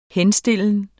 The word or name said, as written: henstillen